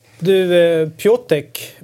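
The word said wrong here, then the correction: Piątek: Visst uttalas det så? – Mm, Piontek. – Ah, okej, okay, förlåt. Det är ett, ett osynligt N i mitten. Ja.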